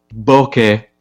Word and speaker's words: bokeh: I was like, I never had the bokeh.